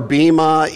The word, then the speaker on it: bema: You'll hear it referred to as bema or bema. Bema is actually the proper pronunciation, but who cares, right? It's all Greek anyway. So it's bema or bema, either one of those will work. And I many times go back and forth, but the proper pronunciation is bema.